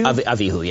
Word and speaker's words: Abihu: Went up Moses and Aaron, Nadab, and Abihu. How do you pronounce it? Does anyone Avihu, know? Avihu. – Abihu? – Avihu, yeah.